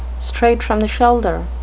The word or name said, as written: straight-from-the-shoulder